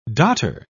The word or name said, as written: dotter